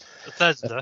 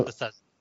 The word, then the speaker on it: Bethesda: Is it Bethesda, whatever they call Softworks? Bethesda. Um, yeah, that's it. Uh, Bethesda.